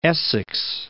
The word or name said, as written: Essex